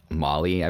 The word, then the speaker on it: Mawle: We found out that he passed away yesterday and that his name was George Mawle, I believe it's pronounced, M-A-W-L-E.